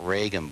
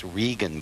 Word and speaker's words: Regan: It's not Reagan books, it's Regan books. – Okay, I say Nirvana, you say... – No, it's you're missing the A. – I know, I say Nirvana, you say Nirvana. – No, it's spelled the same way. You just read the name of the title of the publisher wrong. It's Regan books. It's missing the A that's in Reagan.